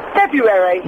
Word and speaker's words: February: F-E-B-R-U-A-R-Y. – Okay, why aren't you saying the first R? – February. – February. – It's not airy, it's airy. – It's February.